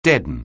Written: deaden

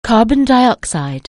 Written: carbon dioxide

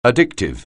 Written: addictive

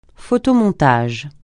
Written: photomontage

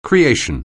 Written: creation

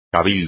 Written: cabildo

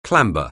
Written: clamber

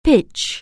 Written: pitch

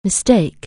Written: mistake

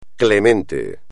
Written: clemente

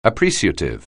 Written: appreciative

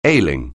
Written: ailing

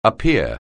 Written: appear

